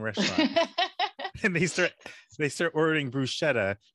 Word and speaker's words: bruschetta: It's like, well, one of those, because the correct pronunciation from an Italian point of view is bruschetta, because that's how you pronounce the CH.